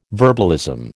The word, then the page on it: verbalism: American English